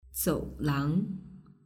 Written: zoulang

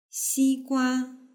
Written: xigua

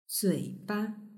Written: zuiba

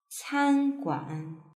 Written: canguan

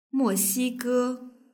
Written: moxige

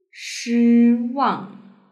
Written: shiwang

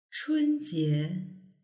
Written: chunjie

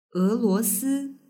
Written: eluosi

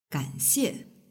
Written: ganxie

